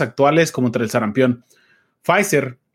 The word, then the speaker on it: Pfizer: Pues Pfizer, o como lo pronuncia tu tío, P Pfizer,